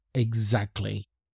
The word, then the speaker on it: exactly: So from now on, you can just say exactly. You don't have to pronounce the T.